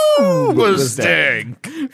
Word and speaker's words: Hoobastank: And you gotta say it like you're saying Cookie Crisp. Also. – Hoobastank.